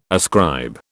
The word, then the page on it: ascribe: British English